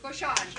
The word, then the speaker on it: Beauchamp: And then also we have with us Paul Beauchere. Did I say it right? – Beauchamp. – Okay. Paul Beauchamp.